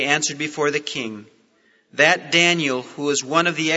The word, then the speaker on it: Daniel: The name Daniel has 3 syllables. If you pronounce it, Daniel. And each one of those syllables in Hebrew has a meaning. Dan means judge. A little E sound in the middle is the Hebrew word for my. And El is the Hebrew word for God.